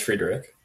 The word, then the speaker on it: Friedrich: Is it Friedrich? – I'm pretty sure it's Friedrich. – Friedrich, right.